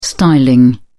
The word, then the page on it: styling: British English